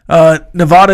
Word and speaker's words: Nevada: Nevada is pronounced with a long A, not a short A. I pronounced it wrong my life until about 5 years ago. It's Nevada. Rhyme Vod with Bod.